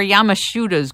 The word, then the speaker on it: Yamashita: Talk about, is it Yamashiro or Yamashita's gold? I don't even know how to pronounce it. – Yeah, Yamashita. – Yamashita.